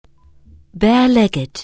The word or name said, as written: barelegged